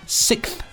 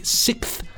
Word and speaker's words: sixth: Please, please ban from saying sixth, and it's spelt here S-I-C-K-T-H. I know it's supposed to be sixth. – Sixth. It's sixth. The X sound that's... – It's so hard. – What he wants in there.